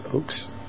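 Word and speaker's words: Ochs: How do you say his name? Phil Oaks? Oaks? – Oaks. – Very sad he hung himself.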